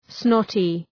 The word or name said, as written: snotty